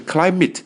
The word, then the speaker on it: climate: 好多 CLI 的单词或者 IM 的单词我们来看看第三个单词 climate，注意发音，不要念成 climate，不是 climate，是 climate。这个 A 发的是 I 的音短音 climate climate 表示气候，气候。